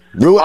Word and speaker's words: Ruoff: Oh, how do you pronounce it? Rua. R U O F F. – Yes, Rua.